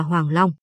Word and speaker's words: hoàng long: là chữ năng dưới có ba chấm, âm đọc là long, nói một cách chính xác là hoàng long.